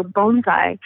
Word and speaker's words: bonsai: But the plant in a pot, you pronounce it like a bone, so bonsai. So you can even correct your friends who mispronounce it, and you can correct them. It's such a great little piece of knowledge to use. So bonsai is the way to pronounce it.